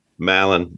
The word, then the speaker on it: Mallen: Mallen.